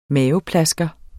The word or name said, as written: maveplasker